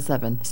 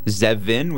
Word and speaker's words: Zevin: Gabrielle Zevin. Storied Life of A.J. Fikre. – Zevin with a V? Or Z-E-V-I-N. – Zevin.